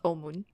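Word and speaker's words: Omun (澳門): In Chinese, it is pronounced Omun in Kanto and Aomen in Mando. – Like Macau? – Yeah, Macau is pronounced Omun.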